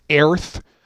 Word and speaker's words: Aerth: So it was set originally in the first time it was issued for Dangerous Journeys, it was in Gary's world of, and I pronounce it Aerith. You know, Gary would take some take and he just swap the letters. So instead of E-A-R-T-H, it was A-E-R-T-H. And it was for the country or in the region of Egypt, A-E-G-Y-P-T.